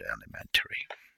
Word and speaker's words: alimentary: Let me double check the word alimentary.